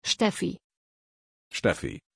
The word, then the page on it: Steffie: German